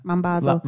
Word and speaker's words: Mambazo: So I went to see Ladysmith Black Mambazo. Is that how you say it? – Yeah. – Mambazo.